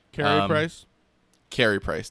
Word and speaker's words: Carey Price: Yeah, so this is one where, again, Corey Price, not what he had been. – Carey Price.